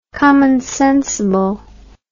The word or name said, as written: commonsensibly